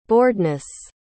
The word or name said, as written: boredness